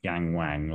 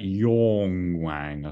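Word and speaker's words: Yangwang: I read this morning that actually Yang Wang isn't pronounced like Yang Wang, like you say it like in a Scouse accent. It's the Yang bit is more like or, so like Yong Wang, I think it is, something like that.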